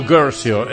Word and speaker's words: Guercio: Qui erano un pochettino perplessi anche loro, soprattutto non era però perplesso il loro produttore James Gercio, anzi, perché in italiano si scrive Guercio ed è, come dire, fa un pochettino ridere, però lo chiamavano Gercio, esattamente questa è la pronuncia esatta, che poi alla fine gli ha praticamente portato via tutti i soldi.